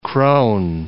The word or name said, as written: kroon